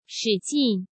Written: Shi Jin

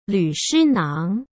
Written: Lu Shinang

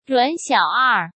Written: Ruan Xiao'er

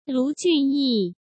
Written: Lu Junyi

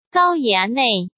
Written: Gao Yanei

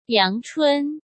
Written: Yang Chun

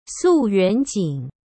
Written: Su Yuanjing

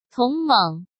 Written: Tong Meng

